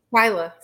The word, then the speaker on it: Twylia: I mean, I think it, like, am I saying your name right? Go ahead and tell me again. Twylia? Is that how you say it? – Twylia, sorry. – Twylia. Everybody. – Oh, I'm sorry. Go ahead. – That's that eye throws everybody off.